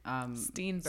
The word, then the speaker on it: Steenburgen: She is, I can never pronounce her last name. I always say something like Burger, something Stanford Burgers. Steenburgen. Sorry about that. It's just a Mexican thing. I cannot Mary Steenburgen.